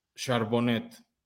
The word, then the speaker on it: Charbonnet: sí puede ser buena opción el señor Charbonnet. Pero así se dice porque es Frenchute el apellido, se escribe Charbonnet.